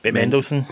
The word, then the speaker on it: Mendelsohn: The other two actors that you mentioned there with Lily James and how do you say his name, Ben Mendelsohn.